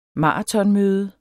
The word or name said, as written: maratonmøde